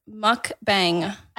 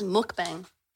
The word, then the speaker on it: mukbang: This is the word mukbang. – I thought it was mukbang. – Oh. Either pronunciation works. Mukbang.